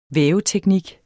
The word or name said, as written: væveteknik